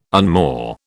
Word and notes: unmoor: British English

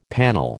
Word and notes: panel: American English

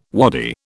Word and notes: wadi: British English

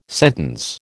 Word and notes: sentence: American English

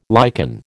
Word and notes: lichen: American English

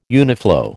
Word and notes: uniflow: American English

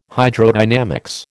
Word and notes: Hydrodynamics: American English

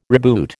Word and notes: reboot: American English

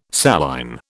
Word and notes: saline: British English

